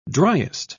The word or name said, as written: driest